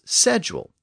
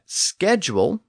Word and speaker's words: schedule: Walker came first, and he said the word should be pronounced as schedule, the traditional pronunciation. But a later edition of his book said that schedule with the SK sound was an acceptable alternative. But he also noted that the word came into English from French, and French tended to pronounce the SCH letter combination as sh.